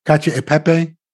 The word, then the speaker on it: cacio e pepe: And he made I always mispronounce it, it's easy, cacio e pepe? – Yeah, that's not mispronounced.